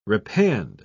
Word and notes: repand: British English

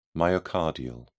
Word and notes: myocardial: British English

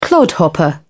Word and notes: clodhopper: British English

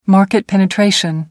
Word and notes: market penetration: American English

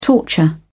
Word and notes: torture: British English

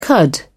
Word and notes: cud: British English

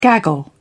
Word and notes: gaggle: British English

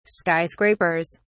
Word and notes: skyscrapers: British English

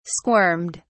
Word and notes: squirmed: British English

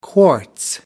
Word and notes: quartz: American English

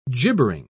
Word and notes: gibbering: British English

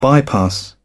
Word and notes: bypass: British English

